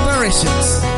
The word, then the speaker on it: Parachutes: Esto es del año 2000, del nuevo milenio de Superchutes. Oh, bueno, Parachutes.